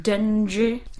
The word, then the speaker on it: Dengie: Actually, it's pronounced Dengie.